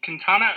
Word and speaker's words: Quintana: Is it Quintana? Because I just love saying that name. – Yeah, you said it right. You got it.